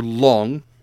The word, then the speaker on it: Long: So the Chinese dragon, or Long, mm-hmm. as they are commonly known, L-O-N-G, and it's pronounced just like that, mm-hmm.